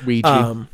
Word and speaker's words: Ouija: Also on January 28th is a game called Elijah? I don't know. O-L-I-J-A. I don't know how to pronounce that. – It's Ouija.